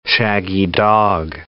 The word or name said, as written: shaggy-dog